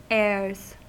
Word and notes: heirs: American English

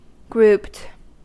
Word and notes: grouped: American English

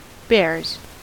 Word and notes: bares: American English

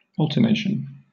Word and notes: alternation: British English

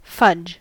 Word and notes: fudge: American English

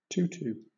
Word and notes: tutu: British English

